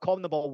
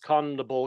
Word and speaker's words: CONMEBOL: We also have CONMEBOL World Cup qualifiers as well.